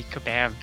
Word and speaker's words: Kabam: It's actually Kabam Games. – Oh, how silly of me. Kabam. Kabam. Kabam. All right, I will probably butcher that about another 12 times in the whole podcast, so it'll be a good time. – We always tell people just think of a bomb going off.